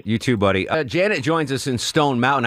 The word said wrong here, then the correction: Jeanette: It's pronounced Jeanette, but I'm fine, thank you. – Oh, so how is that? Is that with two T's and an E at the end? How does that work? – Yes, it's Jeanette. – Jeanette, but yes. I pronounce it Jeanette. – Oh, that's acceptable, just as long as you don't call me Janet.